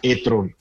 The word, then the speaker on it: e-tron: Realmente Audi lo pronuncia e-tron, como se pronuncia en Alemania, Paco. No lo pronuncia e-tron como sería en inglés.